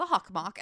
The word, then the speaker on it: Hockomock: Level of normalcy with which I pronounce it. – Very, like, this is the Hockomock.